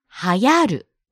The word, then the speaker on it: 流行る: 漢字は当て字。 Irregular reading ですね。流行。 – Trend という漢字を使っていますが、読み方は流行るです。